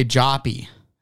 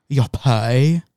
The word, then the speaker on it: Jop: I want to say Joppy, but it's gonna be like uh, it's it's actually European, so it's Yopai, and there's something dumb pronunciation that doesn't need to be there.